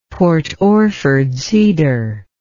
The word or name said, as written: Port Orford cedar